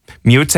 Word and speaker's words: Meute: Mute, I think is the pronunciation of it. It's a German band. It's a strange name, M-E-U-T-E.